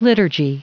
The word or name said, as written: liturgy